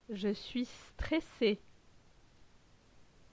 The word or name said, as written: Je suis stresse